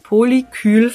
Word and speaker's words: Polykül: Was dürfen wir uns unter einem Polykül vorstellen? Habe ich es richtig gesagt? – Ja. Das hast du richtig gesagt.